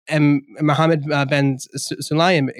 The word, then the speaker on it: Mohammed bin Sulayem: So Mohammed bin Sulayem, excuse me if I mispronounced that. – No, that's his name. You got it.